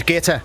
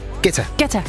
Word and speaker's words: Guetta: Dengang jeg arbejdede på Kommercial Radio, der sendte han engang en fax om, at hans navn udtales Guetta. Og ikke Guetta, som alle os andre havde grund at kalde ham. – Hvis det er fransk, så er det Guetta.